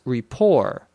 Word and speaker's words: Report: So kind of as a joke, you also pronounce report, R-E-P-O-R-T, as rapport, so that it rhymes with Colbert.